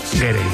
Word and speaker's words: Gere: pensando yo que si te apellidas Ger, bueno, ¿cómo se dice Ger o Gerde? – Gere. Me ha hecho gracia porque has dicho Richard Ger y luego el hijo era Gere. – Yo creo que es Richard Gere.